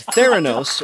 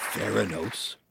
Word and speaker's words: Theranos: It was Theranos, or as Elizabeth Holmes might herself have pronounced it, Theranos.